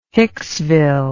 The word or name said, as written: Hicksville